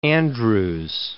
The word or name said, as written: Andrews